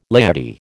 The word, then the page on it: laity: American English